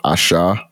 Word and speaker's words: Asa: It's called Asha and it's spelled ASA.